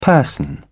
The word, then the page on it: person: British English